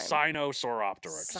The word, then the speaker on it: Sinosauropteryx: Sinosauropteryx. – Sinosauropteryx.